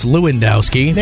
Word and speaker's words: Lewandowski: I was right. It is pronounced Lewandowski. Nailed from it.